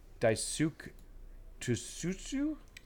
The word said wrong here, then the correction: Daisuke Tsuji: Daisuke Tsuji.